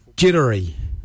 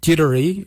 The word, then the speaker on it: djerdari: Jardak is spelled D-J-A-R-D-A-G. Jardak. Grey. We'll now look at the word for brown. Jittery. Jittery is spelled D-J-E-R-D-A-R-I.